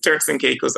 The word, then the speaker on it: Turks and Caicos: First of all, tell us where the Turk Caicos Islands are and how do you say it? – Oh, that's okay. Where is it located? That's okay. You got it right. It's Turks and Caicos Islands.